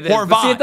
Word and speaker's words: Horvat: Am I saying that correctly? – Now, that's the correct way to say it. – Horvat.